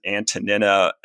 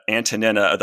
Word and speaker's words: Antonina: I pronounce it Antonina as sort of a little bit of an affectation, I suppose. – This is how the Greeks would pronounce it. – Right. Yeah. The Greek is Antonina.